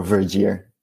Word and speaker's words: Vergeer: They don't pronounce the G as we do, so it's more Vergeer.